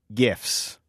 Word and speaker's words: GIFs: The future of advertising could be, hard G, GIFs. – We hear you, Kai Ryssdal, and we have chosen to pronounce the word as GIFs.